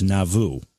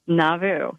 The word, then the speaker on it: Nauvoo: And, you know, you helped me out, too, because I was going to have to ask you how to pronounce the town, and you said it's Nauvoo. – Nauvoo, yeah.